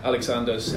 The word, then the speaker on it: Aleksandrs: Aleksandrs, yeah.